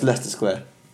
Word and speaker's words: Leicester Square: And well, it's Leicester Square. – Yeah. – For those listening who don't know. – Yeah. – It's Leicester Square.